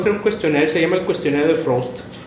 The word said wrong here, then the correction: Proust: De Proust se pronuncia que es un conjunto de preguntas para reflexionar un poco.